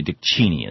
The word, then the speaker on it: Cheney: Actually, Dick Cheney is how he pronounces it. He kind of likes the fact that the rest of us pronounce it wrong.